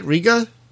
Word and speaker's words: Riga: Did I say it right? Riga, Latvia? – Yep. – Okay.